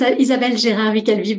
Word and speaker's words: Isabelle Gérard Calvi: So, it's Isabelle Gérard Calvi, but I acknowledge it's a bit difficult to pronounce for a non French speaker.